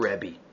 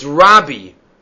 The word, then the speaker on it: Rabbi: You see from here that the proper way to pronounce Rav Yehuda Hanasi's nickname is Rabbi and not Rebbe. Because Rabbi is Rabin. That could be confusing. Rebbe and Rabim is not. But says Rav Yaakov Emdin, it's Rabbi.